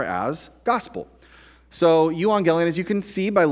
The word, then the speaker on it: euangelion: euangelion,